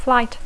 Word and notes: flight: English

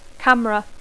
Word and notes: camera: English

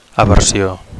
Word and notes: aversio: Catalan

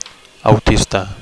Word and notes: autista: Catalan

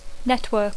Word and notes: network: English

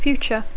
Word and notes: future: English

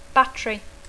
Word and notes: battery: English

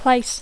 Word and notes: place: English

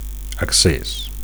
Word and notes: acces: Catalan

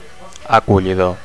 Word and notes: acollidor: Catalan

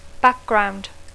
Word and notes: background: English

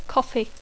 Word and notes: coffee: English

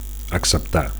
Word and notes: acceptar: Catalan

